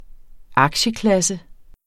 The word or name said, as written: aktieklasse